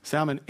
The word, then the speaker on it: Salmon: She ends up marrying this godly man named Sal- Salmon, just like the fish, only pronounced with an L. Salmon.